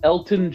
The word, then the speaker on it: Elgton: Next, on day two, at 44, we took Elgton Jenkins. The G is silent.